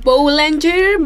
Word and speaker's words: Boulanger: ¿Cómo se pronuncia? ¿Es francés? Boulanger, Boulange. Boulange. – Boulange. No suena la R.